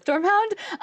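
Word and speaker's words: Sturmhond: So my question is, would we see him as Stormhound? Is it how you say it? – Stormhound.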